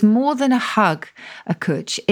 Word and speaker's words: Cwtch: I mean, for example, one of the words you've got, and I don't know how to pronounce this, is C-W-T-C-H, which I assume is a Welsh word. – It is a Welsh word. Cwtch, this one is.